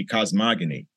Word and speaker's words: cosmogony: Not cosmology, cosmogony. – Yeah. – I thought you were saying it wrong. Says, no, it's cosmogony.